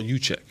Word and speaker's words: Juszczyk: Kyle Juszczyk.